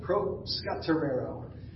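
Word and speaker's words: proscaterero (προσκαρτερέω): The guys talked on us on Tuesday night about this word called proscaterero. It's a little bit tougher to pronounce, but it's proscaterero, and it means devotion.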